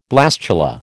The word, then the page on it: blastula: American English